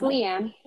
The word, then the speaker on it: LeAnne: I think what I called you LeAnne instead of LeAnna. – No, it's LeAnne.